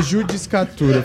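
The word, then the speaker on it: judicatura: É juriscratura. – Juriscratura. – é, é judiscatura, falei errado.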